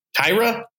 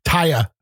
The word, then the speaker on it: Taya: I mean, how do you say your name? Tara Valkyrie? Tyra? – Taya.